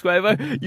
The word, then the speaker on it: Quavo: But when I've said Quavo, you're like, no, I think you'll find it's Quavo. You have ruined me. I've been gaslit. I can't take it anymore. How do you say his name? – It's Quavo.